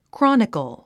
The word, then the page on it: chronicle: krάnikl; クロニコォ